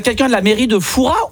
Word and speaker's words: Fouras: Eh bien écoutez, on dit Foura. Ah ben, on ne prononce pas le S. – Et donc on dit bien Père Foura. – Voilà, tout à fait.